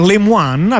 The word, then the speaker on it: Lemoine: Fatto sta che Blake Lemoine, abbiamo concluso che dovrebbe essere la pronuncia corretta. – Sì, potremmo sbagliare la pronuncia, però siccome lui si dichiara un Cajun, quindi un creolo francese della Louisiana, probabilmente utilizza la pronuncia francese per il suo cognome, quindi noi continueremo così, potremmo sbagliarci, però...